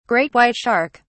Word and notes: great white shark: British English

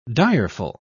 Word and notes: direful: British English

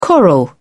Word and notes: coral: British English

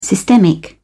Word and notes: systemic: British English

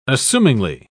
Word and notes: assumingly: British English

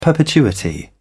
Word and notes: perpetuity: British English

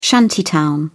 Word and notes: shantytown: British English

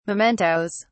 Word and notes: mementoes: British English

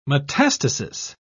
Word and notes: metastasis: British English

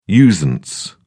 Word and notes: usance: British English

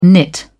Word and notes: nit: British English

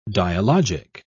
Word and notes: dialogic: British English

